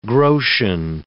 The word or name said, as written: groschen